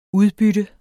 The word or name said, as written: udbytte